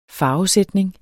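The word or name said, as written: farvesætning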